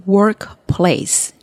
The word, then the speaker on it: workplace: 这个 workplace 这个字啊，有些同学他可能会念成 workplace，就是两个字你都放重音在上面。可是事实上，大家看拼音就知道，它其实是一个字哦，连在一起的。